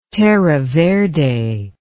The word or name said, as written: terra verde